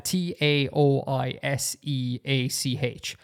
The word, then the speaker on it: Taoiseach: t o t a o i s e a c h i'm willing to bet exactly zero people would come to the conclusion naturally that that's pronounced Taoiseach because there's just so much extra information there that goes contrary to the way a lot of romanizations um, work, you know?